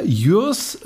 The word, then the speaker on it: Jürs: Und äh, zwar ist er von äh, Jürs Lackiererei, habe ich das so richtig gesagt? – Das hast du richtig gesagt, genau.